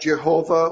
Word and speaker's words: Jehovah: Now, we would say that, pronounce that Jehovah, or some prefer Yahweh, or Yehovah, or some variation of that.